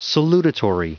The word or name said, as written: salutatory